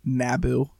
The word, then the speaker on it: Naboo: My go-to answer is Naboo. I like Naboo. I think it's an awesome planet um, Nabu, I guess is the proper way to say it, Nabu.